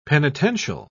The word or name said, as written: penitential